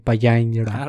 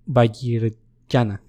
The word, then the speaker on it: Vajrayana: el hinduismo tántrico y el budismo de Vajrayana. – Ah, la verga. – Vajrayana, perdón, lo dije mal.